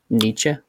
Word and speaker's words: Nietzsche: Nietzsche? – Sure.